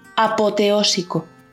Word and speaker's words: apoteósico: Repito, apoteósico. Apoteósico es esdrújula y lleva tilde en la primera O.